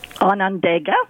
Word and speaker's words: Onondaga: And the pronunciation correctly is? – Onondaga. – Onondaga. All right. – Gotcha. – I should know that. Gosh. – You should. – I know. – You know everything. – And now if you had the native pronunciation, that wouldn't be correct because they call it Onondaga.